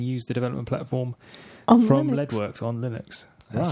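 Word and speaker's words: Leadwerks: Yes, tell us all about Leadworks. – Yes, Leadworks, I think you'll find it's actually pronounced.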